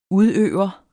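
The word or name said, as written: udøver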